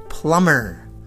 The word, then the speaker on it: plumber: B-O-M-B-E-R, bomber, bomber. D-E-B-T-O-R, detour, detour. P-L-U-M-B-E-R, plumber, plumber. Rhymes with summer, we had that before.